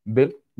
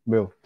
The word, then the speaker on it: Boehl: Is it correctly pronounced like this? Bill. Pedro Boehl. – Pedro Bill. Bill. Bill. – like Bill. Ger- German German surname.